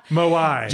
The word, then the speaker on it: Moai: This exotic trail, far out in the Pacific Ocean, takes in the famous Maui statues of the island. – No. – I don't think I said that right still. – No, that's okay. – Moai.